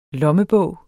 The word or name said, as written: lommebog